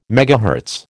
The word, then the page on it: megahertz: American English